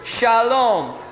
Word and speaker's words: Shalom: You see, in English, whenever we have an unaccented syllable like this one, and the accent is here, we shorten it and we say Shalom. Shalom, you all. Shalom. And you can always tell in the English speaker in Israel. We have to keep it long.